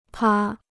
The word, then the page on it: 趴: pā